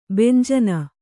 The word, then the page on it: ಭಂಜನ: bhanjana